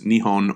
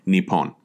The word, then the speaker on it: Nihon: All right, the Japanese pronounce these two characters Nihon or Nippon.